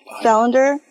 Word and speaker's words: Salander: am I pronouncing that right? Salander? Okay.